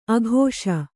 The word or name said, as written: ಆಕಾಶ